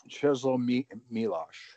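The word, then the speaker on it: Czesław Miłosz: Czeslaw Milosz.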